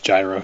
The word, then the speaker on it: gyro: Pronounced gyro. – It's gyro, actually.